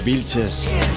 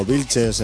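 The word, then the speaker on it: Viltzes: V-I-L-T-Z-E-S. O yo lo pronuncio así, como Vilches en castellano, pero creo que la pronunciación va por ahí más o menos.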